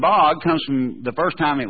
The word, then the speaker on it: bad: Did you know that the Hebrew word for this is the word B-A-D? Now, we would say bad. But the A in Hebrew would have been pronounced ah, like father, bod.